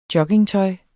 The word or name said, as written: joggingtøj